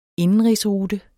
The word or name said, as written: indenrigsrute